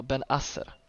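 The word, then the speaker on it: Bennacer: han har då arabiskt påbrå och, och skällde lite på mig för att han, jag för mig att uttalet ska vara Ben Asser.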